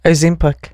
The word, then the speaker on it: Ozempic: Ozempic.